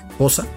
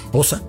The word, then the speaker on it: Xhosa: La melodía Nkosi Sikelel Africa es una melodía de idioma Xhosa, o para pronunciarlo bien, Xhosa. Sí, lo escuchó bien, Xhosa.